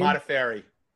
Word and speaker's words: Modafferi: Modafferi.